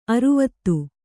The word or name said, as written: ಅರ್ವತಿ